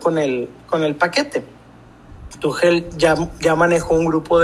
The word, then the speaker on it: Tuchel: se pronuncia Tuchel, no sé exactamente, Marcelo es el que me atreve con, ese tipo de cosas. No, Marlon Reimers, Tomás Tuchel. Ah, perdón, Tuchel.